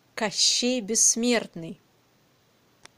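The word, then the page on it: Kashchey Bessmertny: Russian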